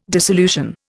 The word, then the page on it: dissolution: Indian